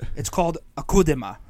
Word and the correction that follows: Akudama: But no, it's pronounced like it's spelled. It's really called Akudama.